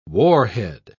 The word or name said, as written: warhead